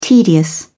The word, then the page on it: tedious: British English